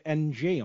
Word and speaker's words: Ng: I think they pronounced it NG on FIFA. – NG, yes.